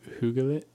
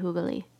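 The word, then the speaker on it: hyggeligt: How'd you say, hoogalit? – It's very hoogly. – Hoogly. – Yes. – You don't pronounce the T.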